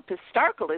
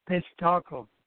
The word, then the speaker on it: Pistarckle: Now, Pistarckle is interesting.